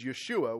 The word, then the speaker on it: Yeshua: In actuality, Jesus' name in Hebrew, if you were to pronounce it, is Yeshua, which sounds less like Jesus and more like Joshua, and that's because it is.